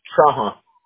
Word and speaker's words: Trahan: Trahan.